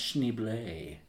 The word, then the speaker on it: Schneebly: And one of the teachers is like, so, am I saying that right, Schneebly? And he's like, no, it's pronounced Schneebly.